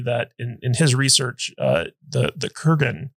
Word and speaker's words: Kurgan: that the Krugan, is that how you pronounce Kurgan. – It? – Kurgan.